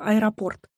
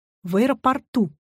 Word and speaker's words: аэропорт: Обратите внимание на слово «аэропорт». Ударение в нем везде неподвижно. Аэропорта, аэропортов, в аэропортах. Но есть одно исключение. В аэропорту.